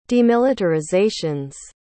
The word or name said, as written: demilitarizations